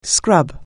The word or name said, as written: scrub